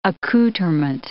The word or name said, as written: accouterment